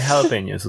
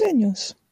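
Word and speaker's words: jalapeño: jalapenos. – Yes. I hate to correct you, uh, both of you, but it's it's pronounced jalapeno. oh yeah, jalapeno. – I yeah, said jalapeno.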